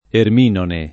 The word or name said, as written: erminone